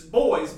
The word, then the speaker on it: Boes: But this one I really like, Country Bows. – B-O-E-S. – Country Bows. – Like, B-O what? – E-S. It's boys, but they just spell it like bows.